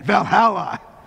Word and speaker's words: Valhalla: And you got to say it like that, Valhalla,